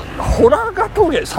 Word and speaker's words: 洞ヶ峠: えー、これをですね、えー、ホラガ峠を決め込むという表現でね、えー、週刊誌なんかで書かれておりますけども、これ、あの、ホラガ峠、山水に同じと書いて、ホラですね。